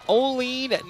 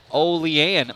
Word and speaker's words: Olean: Uncle P and Company love listening to Jackrabbits from Olean, New York. Olean, my bad.